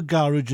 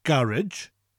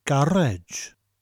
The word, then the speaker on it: garej: It's spelt slightly different, G-A-R-E-J, and we emphasise that last syllable. So it's not garage, but garage. Garage. Almost sounds like you're saying gar, which rhymes with jar, gar, and edge, like the edge of a table or the edge of a surface. But after gar, roll your R.